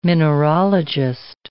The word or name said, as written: mineralogist